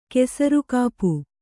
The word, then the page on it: ಕೆಸರುಕಾಪು: kesarukāpu